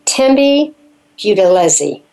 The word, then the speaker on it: Thembi Buthelezi: We're going to take a break, and when we come back, we are talking to Thembi Buthelezi.